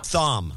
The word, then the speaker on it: Thom: Thom.